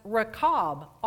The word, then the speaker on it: Rachab: However, in the New Testament, it is spelled differently because it's translated from the Greek to English, and it's pronounced Rachab, R-A-C-H-A-B, from where we get Rachel and other names like that.